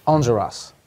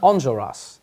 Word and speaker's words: Enjolras: His name is, tell me how you say it. – Enjolras. – I've never said it that way. I've said like Enjolras. – So say it again. – Enjolras. – Enjolras. – It's like that French thing.